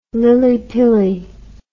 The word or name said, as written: lillypilly